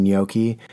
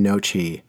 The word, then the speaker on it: gnocchi: But also, I'm really enunciating gnocchi. I believe that's how you say it, but literally up until about five minutes ago, I've been saying nochi the entire time.